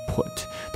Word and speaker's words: put: 再比如说放置这个单词啊，put put，当然这个 put。它的发音会有一点点不同啊，你需要把嘴唇稍微撅起来一点点啊。